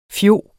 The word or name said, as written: fjog